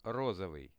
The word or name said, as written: rozovyi